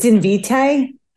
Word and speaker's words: Invitae: Is it Invitae or Invitae? – It's Invitae. I hope I'm saying it right, but I think it's Invitae.